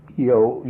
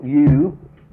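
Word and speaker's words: ewe: Yo, you. – Okay. Use both pronunciations, you and yo? – Yes.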